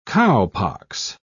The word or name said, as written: cowpox